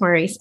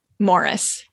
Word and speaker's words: Maurice: I did think it was pronounced Maurice, and I have not read the book. – Yeah. So I guess in England, they pronounce M-A-U-R-I-C-E is Morris.